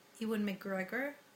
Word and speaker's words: Ewan McGregor: Mills, and it has Ewan McGregor, Did I say that correctly? – Yep.